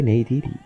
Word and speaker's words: Neytiri: O Neytiri, ¿cómo se dice? Neytiri.